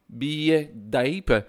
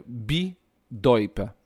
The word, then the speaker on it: Bidoip: Il y a bien entendu Paimpol, mais je vous donnais un autre festival qui était que je prononçais Biedaip. Ou quelque chose comme ça. Eh bien, en fait, j'ai eu la véritable prononciation, mais c'est Bidoip. C'est ça. J'espère que je prononce bien. Donc, Bidoip.